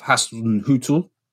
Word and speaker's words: Hasenhüttl: On moving off the team a little bit, I wanted to talk about Ralf Hasenhüttl. Is that the right pronunciation? – Close enough.